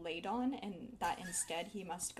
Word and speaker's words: Ladon: Did I say it wrong? – No. I've just been... My brain has been reading it as Landon. There's no A.